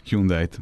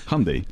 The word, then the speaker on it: Hyundai: Akkor még egyszer idehoznám azt, amikor a Hyundai-t megszakértették az amerikaiaknak. Az egy nagyon egyszerű üzenet volt. Hyundai, like Sunday. – Igen, Hyundai, like Sunday. Így van.